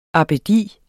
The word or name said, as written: abbedi